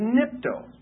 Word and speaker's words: nipto: And the second word wash is nipto. N-I-P-T, long O.